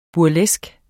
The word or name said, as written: burleske